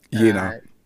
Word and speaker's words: Jena: You would say Jena, yeah. It's most people say it wrong, and it's like Vienna, and then we think it's Austria. – Jena. – Jena, yeah, exactly, or Jenna.